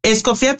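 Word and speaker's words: Escoffié: Escofía, pero como gusta es pronunciarlo porque lo pronuncian de mil formas.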